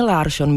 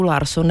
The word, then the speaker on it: Larsson: Tak upoutal hlavně tím, že začal používat výslovnost příjmení Larsson místo českého způsobu Larsson nebo Persson místo Persson. No tak ono od té doby, co se o tom začalo hovořit, to má jako velký průběh a změny.